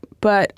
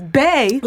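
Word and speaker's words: Bey: People need to stop pronouncing the shorthand for Beyonce. – Listen. – As Bay. – Listen. – Instead of B.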